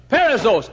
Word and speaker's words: perissos: P-E-R, we'd spell it P-E-R-I-S-S-O-S, perissos.